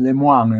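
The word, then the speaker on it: Lemoine: Ahí de vuelta, en mi blog van a encontrar un artículo de un francés que se llama Lemoyne, Maurice Lemoyne en realidad se pronuncia en francés,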